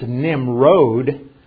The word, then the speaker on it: Nimrod: It's Nimrod. There's a long O there, but that's his name in Hebrew.